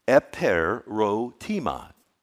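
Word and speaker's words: eperotema: It's pronounced eperotima, eperotima.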